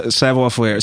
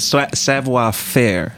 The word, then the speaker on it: savoir faire: So if I'm not mistaken, and I believe I pronounced that totally wrong. – Oh, this is short. It's savoir faire. So it's savoir faire.